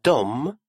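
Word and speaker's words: de: «Дом» – «они», «дом». Такое написание является разговорным. Грамматически верное состоит всего из двух букв «д» и «э», а читается все равно «дом».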